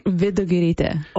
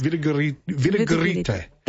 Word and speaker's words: Vidugirite: What is the actual pronunciation? – Vidugirite. – Oh, wow. – Yeah. Well, that's nothing like what Vidugirite. – I said. – Vidugirite. No, you were close. – Vidugirite. – That's it.